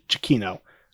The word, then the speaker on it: Giacchino: I've heard like so many pronunciations. – But I have no idea. – I've heard Giacchino. – I've heard that too, but I just listened to an interview. I'm pretty sure he said Giacchino.